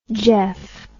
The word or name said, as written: geoff